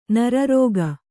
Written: ನರರೋಗಿ

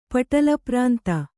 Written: ಪಟಲಪ್ರಾಂತ

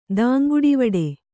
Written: ದಾಂಗುಡಿವಡೆ

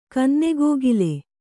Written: ಕನ್ನೆಗ್ಗಿಲು

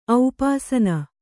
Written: ಔಪಾಸನೆ

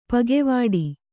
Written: ಪಕ್ಕೆವೆಟ್ಟ

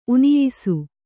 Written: ಉನಿಯಿಸು

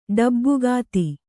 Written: ಡಬ್ಬುಗಾತಿ

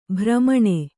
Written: ಭ್ರಮಣಿ

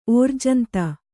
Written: ಓರ್ಜಂತ

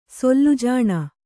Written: ಸೊಲ್ಲುಜಾಣ

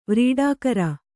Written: ವ್ರೀಡಾಕರ

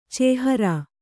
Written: ಚೇಹರಾ

